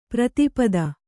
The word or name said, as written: ಪ್ರತಿಪತ್ತು